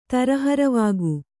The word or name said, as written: ತರಹರವಾಗು